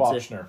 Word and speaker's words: Bochner: Arthur Reeves is voiced by Hart Bochner, or Bochner, I'm not sure how to pronounce Bochner.